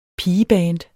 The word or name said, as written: pigeband